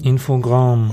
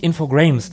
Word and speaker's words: Infogrames: Infogramm. Und ich weiß ehrlich gesagt gar nicht, ob Infogramm richtig ausgesprochen ist. – Also es das heißt nicht Infogrames.